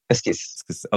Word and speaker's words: Esquisse: And then finally, we have Equis. I'm probably pronouncing that wrong. It's my French is bad. – Esquisse. – Okay, there you go.